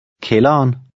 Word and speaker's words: kælderen: With this in mind, try to read number 9, where the D is also silent. – Kælderen.